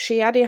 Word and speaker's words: Shehadeh: Und ich bin in dem Verständnis aufgewachsen, dass ich Sheadi heiße, aber natürlich spricht man diesen Namen anders mhm. aus. Mhm. Ich kann es aber nicht, da ich niemals richtig Arabisch gelernt habe.